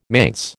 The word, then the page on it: manse: American English